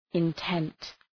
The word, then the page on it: intent: {ın’tent}